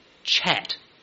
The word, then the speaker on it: kyat: Jill and I, before COVID anyway, went to Myanmar a lot, and the currency in Myanmar is the chat. Not talk, but you spell it K-Y-A-T. Chat, that's how they say it.